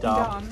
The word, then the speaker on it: Dom: Dom. – D-O-M. Dom.